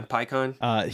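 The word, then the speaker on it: Pikkon: There's that filler saga named, I think it's called the Other World Saga, where it's got like Pecan, I think that's how you say his name, or Pycon. – Oh, yeah, Pycon.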